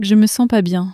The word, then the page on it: je me sens pas bien: French